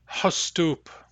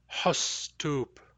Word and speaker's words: Hustup: Hustup. Back H, short U, yes. Hustup means seafoods, foods from the salt water.